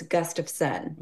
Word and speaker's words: Gustafsson: I say, well, it's like a gust of wind, but it's Gustafsson.